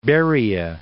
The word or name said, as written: Beriya